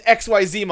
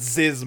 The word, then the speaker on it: XYZ: But then they introduced XYZ monsters. I actually think it's supposed to be pronounced Ziz monsters. I think you're supposed to pronounce it as a word, not say XYZ.